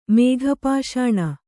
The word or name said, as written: ಮೇಘಪಾಷಾಣ